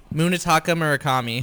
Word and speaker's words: Munetaka Murakami: Munetaka Murakami.